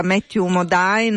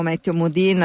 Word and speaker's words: Matthew Modine: L'ossessivo canto dei Marines nella preparazione, nell'allenamento in Full Metal Jacket, 1987, Stanley Kubrick, protagonista Matthew Modine o Matthew Modine, a seconda della pronuncia che volete dargli,